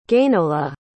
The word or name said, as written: guennola